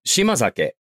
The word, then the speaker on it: shimazake: So, right, shimazake. Shima means island, and sake is, of course, alcohol. It becomes pronounced, the S changes to a Z when it's connected speech. So shimazake becomes shimazake.